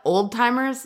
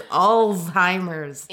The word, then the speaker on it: Alzheimer's: People always call it old timers, but, but it's Alzheimer's.